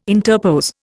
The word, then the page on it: interpose: Indian